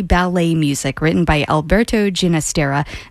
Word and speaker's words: Ginastera: And yes, before someone goes to their email and decides to send an email correcting me on the pronunciation, the composer did prefer the pronunciation with the soft G, Ginastera, because that was the Catalan version.